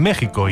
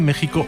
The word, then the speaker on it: México: Sin embargo, quedaron vivos algunos arcaísmos ortográficos en los que se seguía utilizando la X, aunque ya se pronunciara como J, como los casos citados de México o Texas, o también el estado de Oaxaca en el propio México,